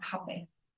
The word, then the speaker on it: Hapé: Yeah, it's just pronounced differently. It's originally called Romepoto. And then in Portuguese, they say it as Rapé. But I was getting flagged on certain search engines for the spelling of it, R-A-P-E. And I also didn't like that vibration of what it felt like when you say it. So I was one that started spelling it with an H and always started, pronouncing it as Hapé.